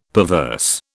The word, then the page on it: perverse: British English